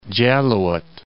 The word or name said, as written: Jaluit